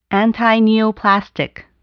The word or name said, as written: antineoplastic